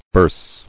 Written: Burse

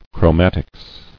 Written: Chromatics

